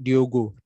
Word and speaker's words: Diogo: Diego Jo, sumpah itu name set yang menurut gue salah pengejaan yang gak keci aja gitu ya. – Iya. – Anda Diego Jota, Diogo, Diogo.